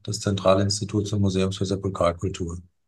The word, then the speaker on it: Sepulkralkultur: Sie sind der Direktor, kommt jetzt ein schwieriges Wort, der Direktor des Zentralinstituts und des Museums für Sepulkralkultur. Richtig ausgesprochen? – Das stimmt.